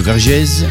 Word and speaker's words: Vergèze: Ça s'écrit V-E-R-G-E-Z-E, Vergèze, France.